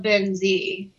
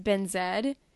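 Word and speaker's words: Benzi: get ladies love Ben Z. – He's very tall. Or as Caitlin was talking about in her podcast, Ben Zed. which is the Canadian pronunciation.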